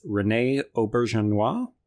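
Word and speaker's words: René Auberjonois: It's Rene Aubergenois. – Oh, thank you for that. – Yeah, that sounds right.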